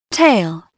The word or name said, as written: tail